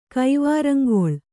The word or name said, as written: ಕೈವಾರಂಗೊಳ್